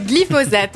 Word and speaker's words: glyphosate: Parfait, glyphosate.